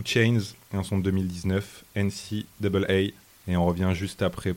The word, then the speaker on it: NCAA: Voilà, ouais, alors juste avant, on prononce quand même NCAA.